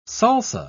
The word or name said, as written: salsa